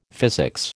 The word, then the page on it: physics: American English